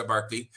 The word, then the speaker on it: Berkeley: Porque se asume que el gobernador de Virginia, William Barclay, o se escribe Berkeley, pero se pronuncia Barclay, era aliado de ciertos grupos porque tenía tratos con ellos para el comercio, ¿no?